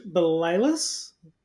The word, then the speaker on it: Balalis: Okay, so we're here with Dr. George Balalis.